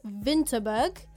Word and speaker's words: Winterberg: The W is pronounced as a V. So like we said earlier, we went to Winterberg, but you pronounce it as Winterberg.